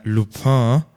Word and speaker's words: Lupin: Und zwar, ich habe nämlich gestern angefangen, Lupin, ich glaube, so spricht man es aus.